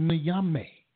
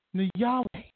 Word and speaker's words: Nyame: The Ashanti, the name of their deity, it's spelled Nyame. But when you do the research, you're reading, you'll find that they didn't pronounce it Nyame. They pronounced it Nyahweh.